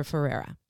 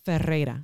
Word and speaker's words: Ferreira: that go on this mission to find um, Father Ferreira. Mm-hmm. Ferreira, excuse me. Yeah. That's how you say it.